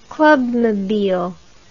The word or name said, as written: clubmobile